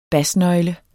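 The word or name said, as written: basnøgle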